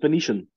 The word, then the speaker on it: Venetian: Arriving in Central Asia in 1289, the Doctor and his companions join the caravan of the famous Venturian explorer Marco Polo. I think it's pronounced Venetian. – Venetian. Oh, yeah.